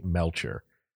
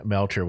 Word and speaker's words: Melcher: So Wilson, the Beach Boy, before then had introduced Manson to a man named Terry Melcher, M-E-L-C-H-E-R, Melcher. – Okay. – I think that's how you say it.